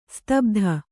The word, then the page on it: ಸ್ತಬ್ಧ: kannada; stabdha